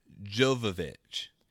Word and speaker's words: Jovovich: Djovovich. I always either add in or lose a syllable in her name. – Yeah, it's a fun game I like to play called Butcher Someone's Name. Yeah, Mia Jovovich.